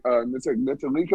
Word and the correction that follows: Ntilikina: or however the fuck. – Oh, Frank Ntilikina. – Ntilikina, bro.